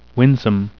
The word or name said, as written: winsome